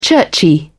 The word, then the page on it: churchy: British English